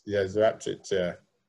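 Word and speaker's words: Joorabchian: Kia Jarabjirin? – Zarabjirin. – Yeah, you can pronounce it better than me.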